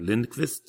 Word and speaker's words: Lindqvist: I can say it right, Lindqvist.